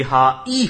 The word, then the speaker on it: hi: ये हाय नहीं, ये हा ई होता है. अंकल आए, आंटी आई, दोनों में फर्क है. ये आए वाला आए नहीं, दिस इज हाई.